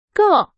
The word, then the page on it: 个: Chinese